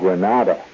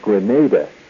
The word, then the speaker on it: Grenada: Don't pronounce the island Granada. Granada is a town in Spain. It has nothing to do with Grenada,